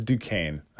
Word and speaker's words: Duquesne: It's Duquesne. That's how that's pronounced. It's very French.